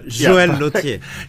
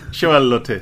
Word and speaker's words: Joël Lautier: Joël Lautier, if I pronounce it correctly. – Perfect. – No. – Joël Lautier. – Joël Lautier, thank you.